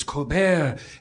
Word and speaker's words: Colbert: So I asked him, is it Colbert or Colbert? And he said, it's the French, it's Colbert.